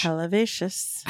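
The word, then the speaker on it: Helvétius: I mean, it's spelled with an H, but it's French, so you forget that H. – Helvetius.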